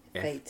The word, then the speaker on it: Feit: F-E-I-T. – Feit. F-E-I-T. – They pronounce it Feit.